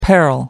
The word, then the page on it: peril: American English